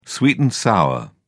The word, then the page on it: sweet and sour: British English